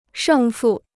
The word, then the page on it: 胜负: shèng fù